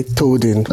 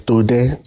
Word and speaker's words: Toden: If you did the abroad, you can say Toden, okay. But the actual pronunciation is Toden.